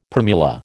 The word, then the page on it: primula: American English